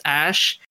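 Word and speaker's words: Æ: I mean, it's just X, the letter X, and the AE is pronounced Ash, and then A12 is my contribution.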